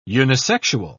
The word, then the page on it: unisexual: British English